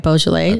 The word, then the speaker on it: Beaujolais: Beaujolais, did I say that right? Beaujolais. – I believe so.